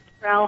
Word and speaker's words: Sproul: S-P-R-O-U-L is pronounced Sproul.